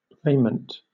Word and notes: raiment: British English

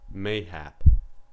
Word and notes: mayhap: American English

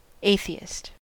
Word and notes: atheist: American English

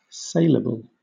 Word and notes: saleable: British English

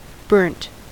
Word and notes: burnt: American English

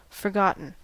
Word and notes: forgotten: American English

